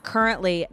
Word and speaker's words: Kerrently: At currently, not like it sounds, like it's spelled, K-E-R-R-E-N-T-L-Y.